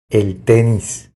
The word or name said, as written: El tenis